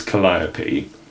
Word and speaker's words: Calliope: So the canonised version is Calliope.